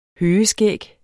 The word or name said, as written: høgeskæg